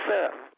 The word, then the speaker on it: Seth: Like I said last week, lots of people say Sid, Cedric. That ain't my name. My name is Seth, S-E-T-H. Very few people say it properly. They just came for some reason say Seth. They can say Beth, but they can't say Seth.